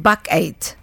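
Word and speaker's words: Bakkeid: Nasıl okunuyor Hayne bu? – Bakayt. Bakayt. Evet.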